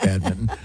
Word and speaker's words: badminton: See, badminton. That's how it's pronounced. – Right. Badminton. – But it's spelled badminton. – Exactly. – Oh, so what happened to that other end? – Well, they dropped it. – It's like February.